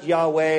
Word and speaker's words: Yahweh: And so sometimes pronounced, you know, it's pronounced Yahweh.